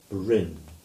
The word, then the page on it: Brynn: English